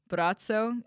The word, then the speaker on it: Braco: Okay, so I was doing some research on this guy named Brazzo. It's spelled B-R-A-C-O, Brazzo, and he is from Croatia, and he is a sight healer.